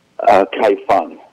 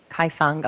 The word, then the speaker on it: Kaifeng: Kaifeng, yeah. – Kaifeng, okay.